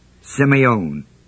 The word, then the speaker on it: semeion: The word token is the word semeion, S-E-M-E-I-O-N, long E. First E is long.